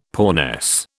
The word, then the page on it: poorness: British English